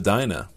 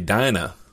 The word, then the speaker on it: Dinah: You just taught me, Bryant, that I can actually pronounce it Dina instead of Dinah.